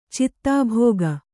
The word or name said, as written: ಚಿತ್ತಾಭೋಗ